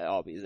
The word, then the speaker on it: Albies: Yeah, Albies.